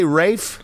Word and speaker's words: Ralph: Don't they say Rafe? – Yeah. – Oh, Rafe, no, not Ranulph.